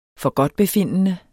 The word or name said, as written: forgodtbefindende